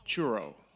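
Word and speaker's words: Truro: It comes from Nova Scotia, okay, and it's pronounced churro.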